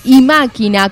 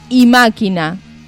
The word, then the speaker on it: Imáquina: Y acá me está aclarando Cristian Farías, que es Imáquina, con acento en la I, al comienzo, Imáquina.